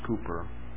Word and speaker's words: Cowper: His name's spelled Cowper, but pronounced Cooper.